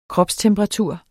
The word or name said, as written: kropstemperatur